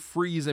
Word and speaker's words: Fries: But sometimes his supervillain name is F-R-E-E-Z-E. – Okay, because sometimes I'm sure I read Mr. Freeze and Mr. Fries. – They pronounce Fries Freeze because they can.